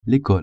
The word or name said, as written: ecole